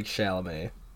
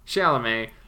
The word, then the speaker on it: Chalamet: How do you say his last name? – I think it's like Chalamet. – Chalamet. Lucas Hedges, Beanie Feldstein.